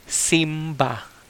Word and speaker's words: simba: It's called simbang gabi. So it came so s- worship service in Cebuano is Simba.